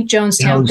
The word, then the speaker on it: Jonestown: Jonestown.